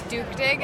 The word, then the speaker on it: duktig: A Swedish man came by the booth today, and he said it's Duktig, and that's how they say it.